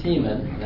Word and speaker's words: Teman: God came from Teman, that's spelled T-E-M-A-N, and the Holy One from Mount Paran, P-A-R-A.